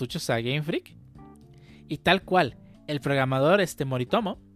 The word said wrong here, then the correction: Morimoto: si se pronuncia así, ¿no? Morimoto.